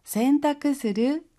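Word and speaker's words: 洗濯: 세탁する 세탁する 우리는 세탁이라고 하는데요. 일본어로는 센타쿠라고 합니다. 씻을 선이라고 하는 한자가 있어요. 근데 우리는 세라는 한자음을 취하고요. 일본어에서는 선이라고 하는 한자음을 취해요. 그래서 세탁을 센타쿠라고 발음한답니다. 스르 연결해서 센타쿠스르 세탁하다.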